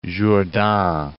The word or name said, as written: Jourdan